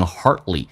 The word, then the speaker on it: hardly: I can hardly，美式发音这个 d 是不发音的，因为 d 跟上面一个辅音在一起，两个辅音只用发后面一个，气流阻塞一下。